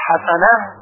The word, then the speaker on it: حسنه: همچنین کلمه حسنه با های جیمی هست، حسنه، پس اگر بگوییم حسنه با های دو چشم این هم غلط هست.